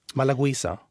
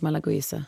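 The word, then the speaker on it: Malagouzia: Hur talar man den där druvan? Malagoisa. – Ja, det skulle jag nog uttala det som sak, Malagoisa.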